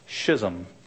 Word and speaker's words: schism: or as our British friends would say, schism. Brutal schism. I like the English pronunciation of schedule. They say schedule. A schedule sounds much better than schedule, but this is schism here for you if you're British.